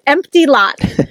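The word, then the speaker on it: M. T. Lott: Probably my favorite Disney planning, pre-planning one, is a window for M period T period Lott, L-O-T-T. And if you say his name fast, yes, it's Empty Lott.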